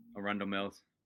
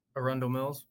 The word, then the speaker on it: Arundel Mills: Gunner and I made our trip to the, I don't know how to pronounce it. It was Andrews Mall? – Arundel Mills. – Arundel Mills. Don't cut that. I already mispronounced it.